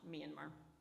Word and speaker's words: Myanmar: It's pronounced Myanmar.